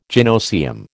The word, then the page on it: gynoecium: American English